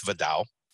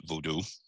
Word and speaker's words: Vodou: And that's where a lot of people, including some of, I have a lot of friends who practice Vodou. I think most Americans pronounce it Voodoo.